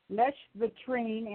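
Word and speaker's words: lèche-vitrine: It's called leche vitrine, and it means window licking.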